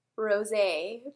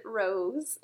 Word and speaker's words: rosé: And I ordered a glass of rosé, but pronounced it rose.